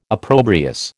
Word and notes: opprobrious: American English

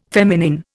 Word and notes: feminine: Indian

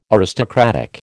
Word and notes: aristocratic: American English

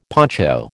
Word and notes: poncho: American English

